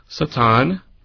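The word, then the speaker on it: Satan (שטן): In fact, the word Satan, pronounced in Hebrew, Satan, that has not been changed.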